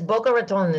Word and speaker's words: Boca Raton: No, that's right. It's Boca Raton. Is that's it. You got it.